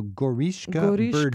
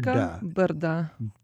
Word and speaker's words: Goriška Brda: G-O-R-I-S-K-A, yes. – And then B-R-D-A. Now, I was taught you have to have a vowel, and this is B-R-D-A, and it's two syllables. Where's the vowel? – We tend to be a little lazy with those. – So, Brda, B-R-D-A, Goriška Brda, and it's just a beautiful, it's also another wine-growing region.